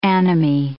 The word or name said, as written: anomy